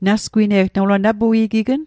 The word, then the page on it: nasgwine'g: Mi'gmaq